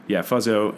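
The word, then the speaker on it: Fuzzzo: Fuzzo, and he would say it's F-U-Z-Z-Z-O, but the middle Z is silent.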